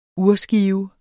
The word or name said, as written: urskive